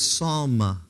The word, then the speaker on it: sawm: It's psalm. I wrote the word psalm, like our psalm, and then put A with a long A over top of it. Psalm is how they pronounce this particular S-A-W-M word.